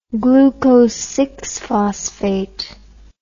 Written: glucose phosphate

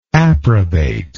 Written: approbator

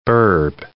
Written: burb

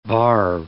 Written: varve

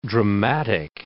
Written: dramatic

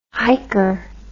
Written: hiker

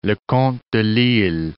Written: Leconte de Lisle

